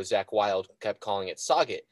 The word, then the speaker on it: Sauget: We call it Sauget. And that's because that's how it's spelled. It's with the French way where they put in 7 letters that are silent. – S-A-U-G-E-T is the spelling of Sauget.